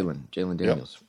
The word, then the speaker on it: Jalen Daniels: I'm going to take their quarterback Jalen Daniels, right? Am I saying that right? – Yep. – Jalen Daniels.